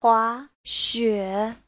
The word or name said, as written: hua2xue3